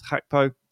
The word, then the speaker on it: Gakpo: This is Cody Gakpo, but we found out recently that he pronounces his name as Gakpo, which is Dutch.